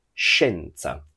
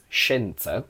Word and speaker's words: scienza: E, but based on the pronunciation, you wouldn't be able to hear the I sound because it's completely silent. We say scienza, just like scendere.